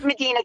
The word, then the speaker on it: Medina: So I do live in the county, and it's actually, I know there is a Medina, Ohio, but we are Medina County. it's spelled exactly the same. You know, Texas, we like to say things a little bit different to confuse you, but it's Medina County.